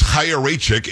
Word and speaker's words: Chaya Raichik: It's it's Chaya Raichik.